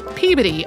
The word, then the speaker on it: Peabody: And as for the other one, listeners may also notice that Holly says Peabody in this episode, while the interviewees say Peabody. George Peabody, who's the museum's namesake, pronounced it Peabody, although there are a lot of institutions named for him that use that pronunciation and others that don't.